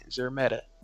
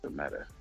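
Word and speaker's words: Szeremeta: The S is silent. Zermeta. – See? Zermeta.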